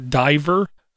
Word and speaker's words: Divyrr: Then we have D-I-V-Y-R-R, Diver.